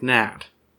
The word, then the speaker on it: gnat: Well, you don't pronounce the G in a gnat.